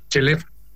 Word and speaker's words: Scheleff: ¿Cómo se pronuncia correctamente su apellido? – Tal cual, Chelef.